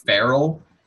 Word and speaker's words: Farrell: Monsignor, yeah, it's like Farrell. – Yeah, like Will Ferrell. – Oh, I was thinking Ferrell, like a feral dog.